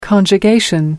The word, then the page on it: conjugation: American English